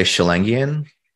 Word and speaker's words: Schellingian: How do you pronounce the word which means of or pertaining to the work of Schelling? – I would say Schellingian.